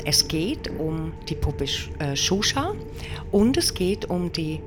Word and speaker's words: Xuxa: Die wird geschrieben X-U-X-A, ausgesprochen wird sie Xuxa, die Spukpuppe.